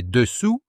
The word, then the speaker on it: dessous: À l'oreille, « dessus » et « dessous » se distinguent par leur dernière voyelle, celle de « dessus » se prononce comme dans « vu », celle de « dessous » comme dans « vous ».